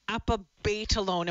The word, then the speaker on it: apabetalone: Tell us about apabetalone. Am I saying it right first off, Donald? – Yes, you are.